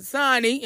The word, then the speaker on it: Xani: And I'm going to let Xani, am I saying that right now? – Yep.